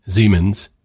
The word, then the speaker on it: Siemens: Everybody that's listening to Breaking Badness in Germany or Austria or any German-speaking country applauds you for pronouncing a German name, Siemens, in the correct way.